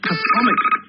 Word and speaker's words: chthonic: Cottrell in his book The Bull of Minos, please ask the team, what does he mean by chthonic? C-H-T-H-O-N-I-C.